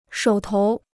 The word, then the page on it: 手头: shǒu tóu